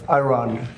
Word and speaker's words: Iran: And that's also important, you know, having to do with the absolutely lovely country of Iran. That's the way they pronounce it.